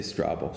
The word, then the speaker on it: Strabo: How do you pronounce it? I say Strabo.